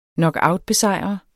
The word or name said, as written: knockoutbesejre